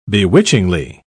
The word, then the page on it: bewitchingly: British English